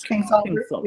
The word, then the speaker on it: Kingsolver: Is it Kingsolver? – Kingsolver.